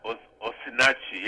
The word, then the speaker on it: Osinachi: do you think that's the way to pronounce it, Osinachi or Nosinaki? Or? – Osinachi, yes. That's the spelling.